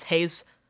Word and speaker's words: Hayes: But the official spelling is Hayes with an E, H-A-Y-E-S, yes.